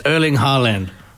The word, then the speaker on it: Erling Haaland: That's Erling Haaland.